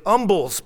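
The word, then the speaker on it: umbles: The other kind of humble pie, the one the old saying is based on, is actually Umble's pie. The H is not pronounced.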